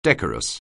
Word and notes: decorous: anglais

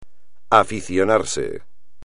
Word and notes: aficionarse: espagnol

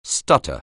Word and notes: stutter: anglais

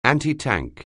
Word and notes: antitank: anglais

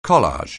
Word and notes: collage: anglais

